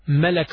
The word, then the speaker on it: ملك: Malak starts with meem, fatha on top of the meem, lam, and fatha on top of the, this is for malak, fatha on top of the lam, then kaf.